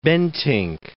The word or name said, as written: Bentinck